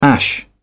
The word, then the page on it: ash: British English